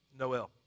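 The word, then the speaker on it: Noël: You know, this is how you pronounce it. You ready? Noel.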